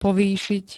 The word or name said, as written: povýšiť